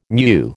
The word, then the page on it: meu: American English